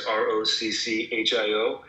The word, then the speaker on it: Rocchio: That's R O C C H I O and I know I probably say it a little bit different than your native Italian speakers. Wouldn't you pronounce my last name like Rocchio? – Rocchio.